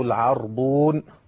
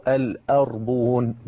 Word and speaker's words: العربون: فهذه ستة أوجه تنطق بها هذه الكلمة، يبقى بيع الأربون أو العربون، وبيع العربون أو الأربون، وبيع العربان أو بيع الأربان. كل هذه أوجه وردت في لغة العرب، يبقى ستة أوجه لكلمة العربون.